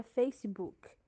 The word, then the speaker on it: Facebook: O primeiro deles é acrescentar uma sílaba com o som de i no final das palavras. Um exemplo é a palavra Facebook, que na verdade se pronuncia Facebook.